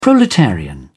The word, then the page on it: proletarian: British English